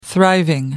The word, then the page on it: thriving: British English